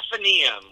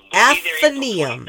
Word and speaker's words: Athenaeum: How do you pronounce it? – Athenaeum. – Athenaeum.